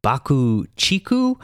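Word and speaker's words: BUCK-TICK: Apparently, their name is actually pronounced Baku Chiku, but I feel like it would be kind of confusing for listeners if we don't just pronounce it the way it's spelled for English.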